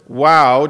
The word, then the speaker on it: waw: This one is, I pronounce it "wow," just because it works as a as an English word as well. We transliterate it as W-A-W or sometimes V-A-V.